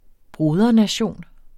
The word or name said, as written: brodernation